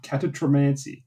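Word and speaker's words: catoptromancy: catatromancy. Is that a word? – I've never heard the word before, but I'm going to go ahead and say you pronounced it perfectly.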